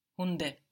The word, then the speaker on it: Hunde: Good, but Hunde? – Hunde. – Hunde, good, because now the D is starting a syllable.